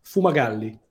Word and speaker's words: Fumagalli: Tell me how to pronounce it again. – Fumagalli.